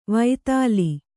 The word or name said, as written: ವೈತಾಲಿ